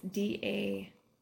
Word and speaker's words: Dacre: Oh, here at the bottom it says written by Harry Dacre. I am not the best pronouncer ever. It's Dacre.